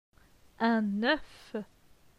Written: Un oeuf

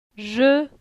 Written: Je

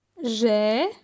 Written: J'ai

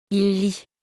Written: il lit

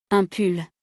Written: Un pull

